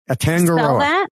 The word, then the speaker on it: Atangaroa: Atangaroa. – Spell that? – A-T-A-N-G-A-R-O-A.